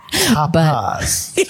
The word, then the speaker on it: tapas: It's not really tapas. It's kind of a mix of all three. – Mm. – So I thought you said topless for a minute. – Tapas.